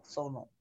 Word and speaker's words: Sonal: I feel like say hormonal in your mind. Hormonal, sonal. Hormonal, mono, sonal. Or tonal. – Sonal.